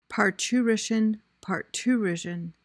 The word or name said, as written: parturition